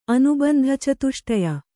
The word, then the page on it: ಅನುಬಂಧಚತುಷ್ಟಯ: anubandhacatuṣṭaya